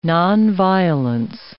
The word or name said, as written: nonviolence